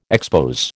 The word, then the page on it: expose: American English